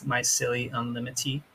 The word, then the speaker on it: Mycelii Unlimited: So I have been asked by many customers how we pronounce our business name, and we here pronounce it as Mycelii Unlimited. I know we do understand you can pronounce it however you like. We know we understand what you're saying, but just try to think of it as like saying the word Illuminati. We just end each word with that, you know, E at the end kind of.